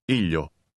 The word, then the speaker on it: ήλιο: Listen and repeat. – Έχω ήλιο. Ήλιο. Ήλιο. Έχω ήλιο. – It's like the long E sound in the words see or feel.